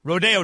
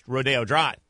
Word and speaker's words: Rodeo Drive: So we ended up in Beverly Hills, Rodeo Drive, right? Everywhere else that word is pronounced rodeo, but out in California it's pronounced Rodeo Drive.